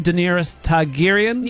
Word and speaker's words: Daenerys Targaryen: Daenerys Targaryen? – You crushed it.